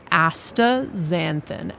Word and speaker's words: astaxanthin: It's astaxanthin. Astaxanthin. So you just take that X, turn it into a Z, and you're ready to roll. And that's how in North America we pronounce it.